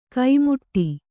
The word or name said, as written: ಕೈಮುಟ್ಟಿ